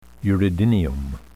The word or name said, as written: uredinium